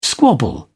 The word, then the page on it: squabble: British English